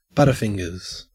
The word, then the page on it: butterfingers: Australian English